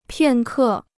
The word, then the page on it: 片刻: piàn kè